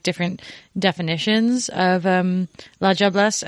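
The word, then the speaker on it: diablesse: So it's pronounced with like a J sound, like la jobless, but it is spelled not that, with a D. – Yeah, it's D-I-A-B-L-E-S-S-E.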